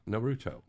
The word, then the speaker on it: Naruto: Yeah, Naruto.